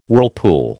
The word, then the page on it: whirlpool: American English